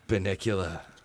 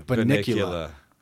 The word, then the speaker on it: Bunnicula: Like their maw is just dripping with Bunnacula. – That is scary. – Bunnicula. – Yeah. I pronounced it wrong. – Bunnicula. That's right, though.